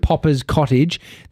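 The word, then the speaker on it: Poppas Cottage: P-O-P-P-A-S, Cottage. Popper's Cottage.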